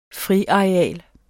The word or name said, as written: friareal